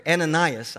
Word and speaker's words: Ananias: And he said, and he has seen in a vision a man named Ananias come in. I'm sorry, Ananias. I've been pronouncing his name wrong for some reason. You know why? Because I'm getting the Korean pronunciation and English pronunciation messed up, and you're giving me a lot of grace because no one yelled out, no!